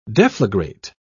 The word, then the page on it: deflagrate: British English